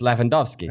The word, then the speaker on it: Lewandowski: Lewandowski. – It's Lewandowski.